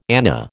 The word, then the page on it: anna: American English